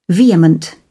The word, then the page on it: vehement: British English